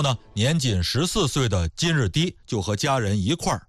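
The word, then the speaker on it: 金日磾: 在这儿啊，我们稍微再多说一句啊，就是我看有的纪录片里边呢把这个金日低读成是金日密，我查了相关的这个资料没找到这个密字的发音的出处，所以咱们还是说成是金日低吧。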